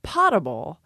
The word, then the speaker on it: potable: A word that someone pointed out to me the other day that they weren't sure how to pronounce was P-O-T-A-B-L-E in reference to water, which given the spelling, you really want to say potable, but in fact, it's potable.